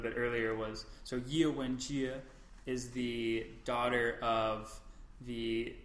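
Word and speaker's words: Wenjie: It was more just like, now I've already forgotten how to pronounce Ye Wenji. – Wenjia.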